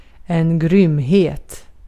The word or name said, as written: grymhet